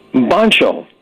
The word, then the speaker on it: Boncho: It rhymes with poncho, which is fabulous. – How do you spell it, Fred? – B-O-N-C-H-O. – Bong Cho.